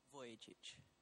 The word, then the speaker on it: Vujicic: I got out my phone, and that night we had a guy named Nick Vukovic. I cannot pronounce his name. How pronounce it for me? – Vujicic. – There you go.